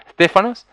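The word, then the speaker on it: Stéphanos: Estéfanos, así tenemos como el hijo de nuestro hermano Johnny, Estéfanos. Bueno, esa es la pronunciación del vocablo, que significa esa corona, corona como si fuera de laureles y guirnaldas.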